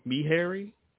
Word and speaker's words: Meharry: and Meharry yeah. – Medical College, which is in Tennessee, in Nashville. – What was that? What kind? Did you say Meharry? – M-E, yeah, me like Meharry, M-E-H-A-R-R-Y. – Okay, just spell it, yeah. Okay. – M-E-H-A-R-R-Y. M-E-H-A-R-R-Y.